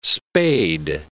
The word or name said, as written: spade